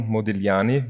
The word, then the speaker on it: Modigliani: Es handelt sich um den liegenden Akt auf der linken Seite von Amadeo Modigliani, wie man ihn ausspricht, was wir vorher noch klären haben müssen. Aber das ist anscheinend die richtige Aussprache.